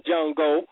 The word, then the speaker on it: Django: And then, you know, Jamie said, D-J-A-N-G-O, and the D is silent.